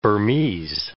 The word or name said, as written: Burmese